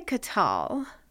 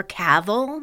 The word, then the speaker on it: Cathal: Or Cavil, maybe? – Oh, Cavil. Okay. – Try it, but take the letter T out of the middle.